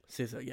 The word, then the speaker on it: SZA: SZA, yeah.